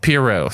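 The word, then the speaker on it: Pierrot: Like, shout out to Studio Perio, or Periot. However you pronounce it. – Piero. – Studio Piero.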